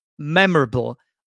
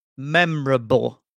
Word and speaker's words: memorable: Now, regarding the pronunciation of this adjective, the first syllable is stressed, so memorable. Also notice that sometimes British people pronounce it only as 3 syllables, memorable, memorable, rather than saying memorable. They say just memorable.